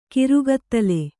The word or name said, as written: ಕರ್ಗತ್ತಲೆ